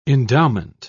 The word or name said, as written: endowment